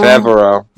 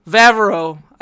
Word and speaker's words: Favreau: Favreau. – Favreau. – Favreau.